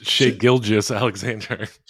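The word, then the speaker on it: Shai Gilgeous-Alexander: Shea Gillis? It's Shai Gilgeous Alexander.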